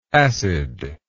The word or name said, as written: acidness